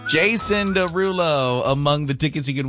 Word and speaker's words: Jason Derulo: Jason Derulo.